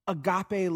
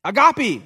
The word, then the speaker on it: agape: We learned about God's unconditional agape love. Did I say it right? No, I didn't say it right. I'm sorry. We learned about his unconditional love. Agape.